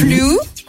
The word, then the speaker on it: flew: Alors, en soi, je pense qu'elle a le bon mot, c'est la prononciation qui pose un petit problème. On est flou. – Alors, attends. To fly. – Oui. – Flew.